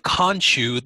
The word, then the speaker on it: Khonshu: But then revives, and the first thing he sees is the statue of Khonshu, the moon god. – Okay. – Oh my god. – K-O-N-S-H-U, right? – K-H-O-N-S-H-U. – Okay, K-H-O-N.